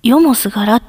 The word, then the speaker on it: 終夜: 意味はね、夜通しとか、日が暮れて空が暗くなってから日が昇るまでみたいな、そういう意味なんですけど、終わるに夜、終夜って書いて、よすがら、よもすがらって読むんだそうです。